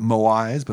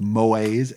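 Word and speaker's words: moai: I used to pronounce it Moais, but I've now heard it pronounced Moais, M-O-A-I-S, Moais, which are small groups of people that you would get to know when you were kids.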